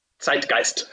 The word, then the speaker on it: Zeitgeist: The Zeitgeist? Yeah, you pronounce it Zeitgeist.